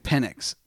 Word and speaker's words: Penix: You got to give it to uh to Phoenix. Uh, the I am pronouncing that right, Phoenix. Penix? Penix. Penix. I knew I was pronouncing it wrong.